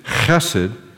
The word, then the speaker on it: chesed: So the H is a rough H. Hate. Chesed. Can you say that? Chesed.